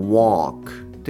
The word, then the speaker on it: walk: ここはあの by walk まあ歩いてっていうことであの walk という方の発音を使っていただきたいと思います。